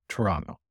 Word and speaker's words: Toronto: Toronto. Sorry. You don't pronounce the second T, apparently.